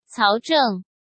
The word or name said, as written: Cao Zheng